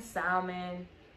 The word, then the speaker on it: salmon: Yeah, you could do chicken, salmon. We go salmon. Apparently, the L is silent. – Mm-hmm. – Like the K and knife.